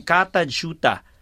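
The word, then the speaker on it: Kata Tjuta: Katajuta yun pa na-pronounce siya. Pero pag in-spell siya, K-A-T-A, isang word na yun, tapos another word, T-J-U-T-A.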